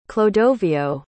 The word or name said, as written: clodoveo